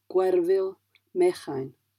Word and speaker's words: Gwerfyn Mechain: In terms of stress, most words are stressed on the penultimate syllable. So, for instance, Gwerfyn Mechain.